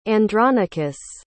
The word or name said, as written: Andronicus